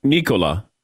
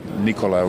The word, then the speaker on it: Nikola: Vucevic says we have been mispronouncing your name all along. It is not Nikola. It's Nikola.